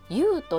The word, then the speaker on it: 遊: 工房のお名前、遊ぶと生まれると書いてゆうと読むんですね。